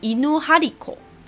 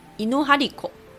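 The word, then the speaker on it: いぬはりこ: 有一种传说中的神兽，它叫做犬张子，犬就是狗嘛，张就是弓长张，然后子就是小孩子的子，日文念作伊努哈里口。伊努哈利口，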